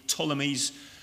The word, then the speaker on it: Ptolemies: It's one of those funny words that starts with P, but it's a silent P. Ptolemies.